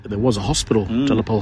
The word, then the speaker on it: de la Pole: The French pronunciation, de la Pole.